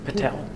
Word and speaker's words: Patel: Nikunj Patel. – Can you spell? – N-I-K-U-N-J Patel, P-A-T-E-L.